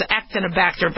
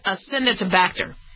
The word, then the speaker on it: Acinetobacter: I know, I used to mispronounce it as Actinobacter, but that's not how to pronounce it. It's Acinetobacter.